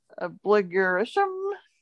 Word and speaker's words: abligurition: And our last word is obligurishum. It's spelled A-B-L-I-G-U-R-I-T-I-O-N. Obligurishum.